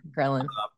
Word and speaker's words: ghrelin: Yeah, ghrelin.